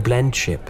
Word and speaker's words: blendship: blendship. – That did make me sad, Your Highness. – That's beautiful. Blemship. Am I saying it right? Blemship. – Blendship. – B- Blend.